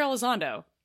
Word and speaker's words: Elizondo: and I just kept saying his last name wrong, and I felt stupid as I was listening back to the episode, because I said it wrong, like, probably 3 times. And I was like, cool, that's embarrassing. His name's Hector Elizondo, and he's awesome, and that's how his name's pronounced, I'm pretty sure, so...